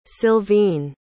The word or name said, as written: silvine